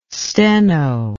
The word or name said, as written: steno